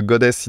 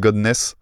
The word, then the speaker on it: goddess: Au lieu de goddess, ils disent godness, je ah, crois.